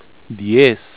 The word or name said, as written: diez